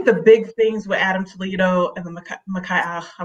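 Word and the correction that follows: Ma'Khia: am probably saying her name wrong, makaya Bryant situations are that they were so young.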